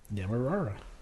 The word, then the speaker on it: Demerara: Demerara. That's the English pronunciation.